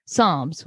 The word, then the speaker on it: Psalms: Tonight, we're covering episodes 1 and 2, titled Book 1, Genesis, and Book 2, Psalms, which honestly, had to make sure that's how I was pronouncing it correctly. That P threw me off.